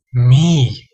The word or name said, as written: mii